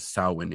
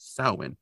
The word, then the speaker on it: Samhain: And Mulder obviously pronounces Samhain incorrectly because it's not Samhain, it's Samhain.